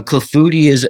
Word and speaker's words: clafoutis: And the clafoutis, is that how you pronounce it? – Clafoutis.